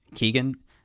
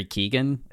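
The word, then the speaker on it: Keoghan: How do we say his last name again? – Keegan? – Barry Keegan.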